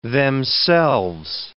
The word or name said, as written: themselves